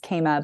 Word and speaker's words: Kama: So, um, those are those are the the multiple definitions of, and then you were not wrong to pronounce it Mukema, right? Because my first name's Kema, but um, kama is the proper pronunciation of that word.